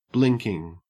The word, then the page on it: blinking: Australian English